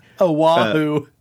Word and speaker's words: Oahu: A truly mysterious site, this cave is located on Aua, leeward's coast, north of Wanae. – Oahu. – What? Oahu? – It's Oahu.